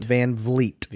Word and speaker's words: VanVleet: Fred VanVleet has basically held Steph Curry these last 2 games to 10 fucking points. – VanVleet or VanVleet? – VanVleet.